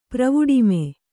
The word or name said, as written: ಪ್ರವುಡಿಮೆ